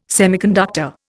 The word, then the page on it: semiconductor: Indian